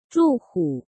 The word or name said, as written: Zhu Hu